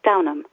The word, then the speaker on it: Downham: My surname has an H in it. You'd think it might be pronounced Downham, but the H is silent, so you pronounce it Downham.